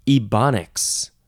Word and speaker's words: Ebonics: This next word, this is definitely getting a link in the show notes, ebonics. Or ebonics, or ebonics. Ebonics, I think that's how most people say, with the long E sound. Capital E, B-O-N-I-C-S.